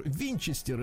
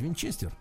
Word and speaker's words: Винчестер: Ну вот, в 1810 году Оливер Винчестер, это американский изобретатель и производитель стрелкового оружия, ну, у нас принято ударение по-другому ставить, Винчестер.